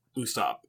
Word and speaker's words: Usopp: Who, how do you say his name? Is it Usopp? It's Usopp, right? Not Usopp? – Usopp, yeah. – Usopp. Okay, I just want to make sure, because it didn't look like an Usopp to me.